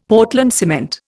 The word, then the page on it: Portland cement: Indian